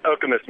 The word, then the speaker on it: Okemos: Andy's with us from Okemos. Am I pronouncing that right? In Michigan? – It's Okemos, Michigan. – Okemos.